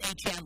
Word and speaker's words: Etienne: who now wants his name to be pronounced Etienne like Devon.